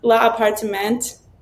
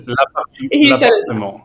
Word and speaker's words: L'Appartement: La Appartement. Is that correct? – Yeah. – No. – It's l'appartement. L'appartement 4F.